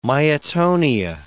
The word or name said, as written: myotonia